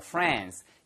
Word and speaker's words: France: 자, 그렇다면 포인트는 이게 발음 하나만 주의하세요. 프랑스 아니고요. France. 영국식 발음이고요. 예, 물론 French 스타일로 France 이렇게 발음하셔도 좋긴 하겠지만 영국식 영화에서 미국식 영화에선 당연히 이걸 France 아니면 France 이렇게 발음하겠죠.